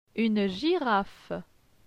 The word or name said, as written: Une girafe